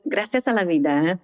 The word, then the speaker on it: Gracias a la Vida: So Gracias a la Vida, thanks for pronouncing it so nicely.